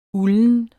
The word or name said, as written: ulden